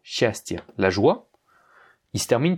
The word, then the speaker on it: счастье: Regarde, c'est le dernier paragraphe, le yé à la fin de счастье, la joie, il n'est pas accentué. Pourtant, il se prononce bien yé. J'ai pas dit счастье, j'ai dit счастье. Hein, c'est bien счастье. Parce que, comme il est en fin de mot, il va rester tel quel, il ne va pas se prononcer comme un i, la règle ne s'applique pas.